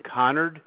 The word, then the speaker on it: Conard: My name is Barth, as in boy, B-A-R-T-H. Last name, Connard, C-O-N-A-R-D. – You said C-A-N-O-R-D? – C-O-N-A-R-D.